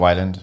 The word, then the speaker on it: Weiland: Scott Weiland.